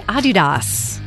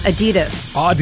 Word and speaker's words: Adidas: Did you know that most of us pronounce Adidas wrong? It's actually supposed to be Adidas. – Really? – Yeah, slight emphasis on the first syllable, not Adidas. – Adidas. All right. – Yeah, it's German.